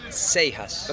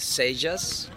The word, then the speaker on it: Cejas: Yeah, I can get away with Cejas, which is you pronounce a J in English, but the funny one is Saijas. It's with an I and with an A, and it's not an A. So yeah, Saijas or Saijas is fine.